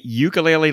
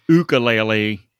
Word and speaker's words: ukulele: Do you say ukulele like a U or ukulele? How's it pronounced? – There's a bit of debate here. – All right. – The Hawaiians say ukulele, and a lot of people on the mainland where you are say ukulele as well.